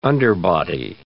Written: underbody

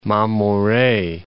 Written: Mamore

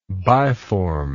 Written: biformity

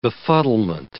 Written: befuddlement

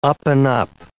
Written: up-and-up

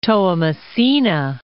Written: Toamasina

